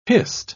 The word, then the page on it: hist: British English